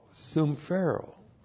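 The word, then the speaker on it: sumphero (συμφέρω): The key word here is sumphero. S-U-M-P-H-E-R, long O, sumphero.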